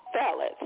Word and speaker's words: phthalates: And one of the chemicals is I'm not sure if I'm pronouncing this correctly, but is it phthalates? Is that how you pronounce it? – Phthalates. – Okay, thank you.